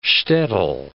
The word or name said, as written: shtetel